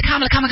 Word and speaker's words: Kamala: Oh, they don't even know how to pronounce her name. Hey, so it's Kamala.